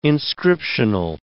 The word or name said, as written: inscriptional